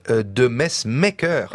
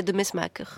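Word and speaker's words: de Mesmaeker: Ouais, là, vous prononcez comment votre personnage? – Bah, moi, je disais de Mesmaquer, comme tous les Français. – Et alors, en Belgique, c'est de Mesmaquer.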